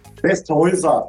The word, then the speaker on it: Westhäuser: Und habe ich das eigentlich richtig gesagt? Westhäuser oder Westhausener? Wie ist das bei euch? – Westhäuser. Wir sind im fast Fränkischen.